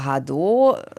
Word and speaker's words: Hado: Ja, also Hado äh, wird es ausgesprochen. Das heißt Welle und das Ganze bedeutet so viel wie Wellenbewegung.